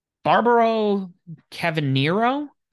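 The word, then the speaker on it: Cavernario: Barbaro Cavanero? – Yeah, you said it right the first time there.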